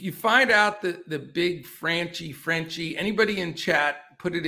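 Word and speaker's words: Franchy: Yeah, so I think it is Franchi, but it's just they pronounce it with an accent. So interesting. All right, we'll stick with Franchi Cordero.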